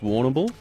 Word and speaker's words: Warrnambool: Am I saying that right? Winamble. I've got that right. Give me a thumbs up. I think that's good. Winamble. Is that where Angus's mate's from? – I believe it's Warrnambool. – Warrnambool. – Yeah. – Oh, I'm saying it right. Okay, Warrnambool. Thank you.